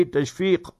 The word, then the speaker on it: Tashfiq: So Tashfiq, only thing you should remember when you spell it in English, then in the ending F-I-Q. Sometimes people will spell it with the K, so that is incorrect. It should be Tashfiq with the Qaf.